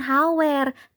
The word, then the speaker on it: hour: Yang kedua, ada kata hour yang berarti jam. Nah, bacanya itu bukan hour ataupun hour, tapi yang benar itu bacanya hour, hour, hour. Once again, huruf hanya nggak perlu dibaca.